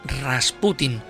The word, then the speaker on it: Rasputin: Y la primera cuestión que abordamos es su apellido, de si es Rasputin o Rasputin. Lo cierto es que la pronunciación correcta es Rasputin.